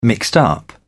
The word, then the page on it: mixed up: British English